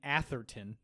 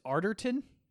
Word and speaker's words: Arterton: You keep saying Atherton. – Is it not? – I believe it's Arterton. There's no H.